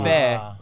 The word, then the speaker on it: Fe: But Fe, for those of you who are bilingual, is how you say faith in Spanish. – Oh. – So, Fe is faith.